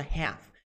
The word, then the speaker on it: half: O que significa half? Half significa metade. Metade de algo. Cuidado, pronúncia não é half. Half. Não. É half. Half. Half.